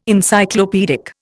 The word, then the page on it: Encyclopaedic: Indian